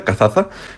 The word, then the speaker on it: Cazaza: Bueno, seguimos con Cazaza. ¿Lo he pronunciado bien? – Sí, esta no es muy conocida, Cazaza, fue también ocupada en 1505, uh-huh.